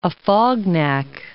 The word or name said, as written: Afognak